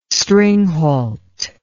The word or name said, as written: stringhalt